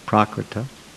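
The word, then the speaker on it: prākṛta: Then Prabhupada says there's a gulf of difference between the two qualities of sound, namely prakrita, that's prakriti but with a long A, prakrita, of the nature of prakriti, of material nature, and aprakrita, non-material.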